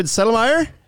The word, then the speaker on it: Settlemyer: We've got, and correct me if I'm saying this wrong, but David Settlemyer from LS Underground is joining us today. – You got it.